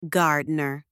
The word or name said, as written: gardener